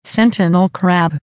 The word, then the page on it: sentinel crab: American English